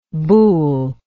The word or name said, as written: Bul